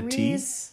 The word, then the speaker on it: Riz: So it's like Ritz, but without the Riz. – T? So Riz Ahmed. – Riz Ahmed. – Riz Ahmed.